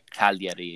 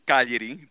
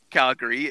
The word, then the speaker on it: Cagliari: So if I do say Calgary, it sounds like the city of Calgary.